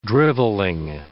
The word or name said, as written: drivelling